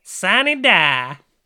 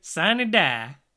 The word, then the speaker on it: sine die: Now, how do we say it in Oklahoma, though? – Sine Die. – Oh, yeah, that is correct. So if you want to say it correctly, do your best Andrea Bocelli impression. Sine Die. – Yeah, Sine Die. Or just be an Okie and say, Sunny Die.